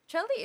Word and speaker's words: Coeli: So it's spelled as C-O-E-L-I. And most people, of course, they think that it's pronounced as Coeli. But yeah, it's pronounced as Chelly.